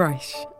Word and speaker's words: Reich: Steve Reich, and I now realise that is how you pronounce his name, is one of the pioneers of minimalism, and it's hard to understate his influence.